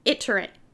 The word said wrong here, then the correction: itinerant: Itinerant. – Itinerant.